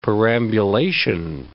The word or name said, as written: perambulation